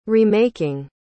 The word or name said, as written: remaking